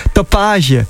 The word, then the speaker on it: Tapage: Ik had hem net nog even de telefoon en dan zeg je, hoe spreek je je naam nou eigenlijk uit? Hij zegt Tapage. – Nou, voor mij is het Franse, zegt hij. Dat zegt hij tegen mij.